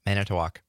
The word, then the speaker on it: Manitowoc: Yeah, Manitowoc.